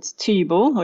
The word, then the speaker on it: Thybo: But there's one called Tybo, which is spelled T-H-Y-B-O, And it's a very umami rich kind of crystallized cheese.